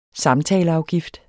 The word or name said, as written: samtaleafgift